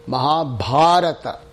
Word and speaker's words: Mahabharata: Don't call it Bharata, it's Mahabharata. – Mahabharata. – Yeah, okay, because if you mispronounce it, then it means nothing. Just like any other language, if you mispronounce it, it means nothing or something different.